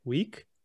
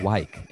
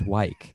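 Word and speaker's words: Weik: You know, that guy spent his whole life saying, no, it's pronounced Wyke. It's Wyke.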